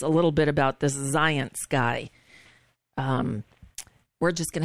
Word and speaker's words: Zients: His replacement is a guy named Jeff Zients. Zients rhymes with science.